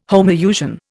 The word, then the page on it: homousian: Indian